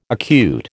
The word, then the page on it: acute: American English